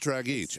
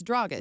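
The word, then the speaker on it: Dragić: The funny thing is, though, is that when I say it wrong, I know I say it wrong. But I know this because he was in Phoenix forever while I was there. It's Dragic.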